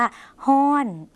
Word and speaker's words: ฮ้อน: คำว่าร้อนในภาษาไทยนะคะภาษาลาวออกเสียงว่าฮ้อน